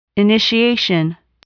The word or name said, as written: initiation